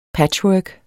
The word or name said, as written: patchwork